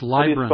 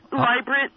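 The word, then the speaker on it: Leibrandt: That's Librant. – How do you spell that? – Excuse me? – Labyrinth? – Librant, yeah, Librant Street and Wave Lane Alley at the corner. – I think it's L-I-E or L-E-I-B-R-A-N-D.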